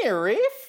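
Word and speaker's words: NIIRYF: Those are the letters for Not If I Reboot You First, and it's pronounced Nerif.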